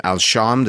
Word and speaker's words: Al-Sham: The West Bank, you know, we talked about Urdun, Jordan, and you mentioned the western portion, which was part of, and I'm going to butcher this name, Al-Sham, the Syrian. Did I say that correctly? There you go. – Yes.